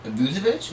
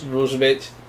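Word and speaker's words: Vučević: Uh, Volvo Volvo. Vucevic. Vucevic.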